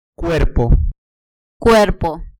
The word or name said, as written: cuerpo